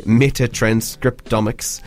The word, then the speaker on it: metatranscriptomics: So to do so, you're sampling 1,300 birds using metatranscriptomics. – Yes. – I think I said that right. – Yes, you did, yeah.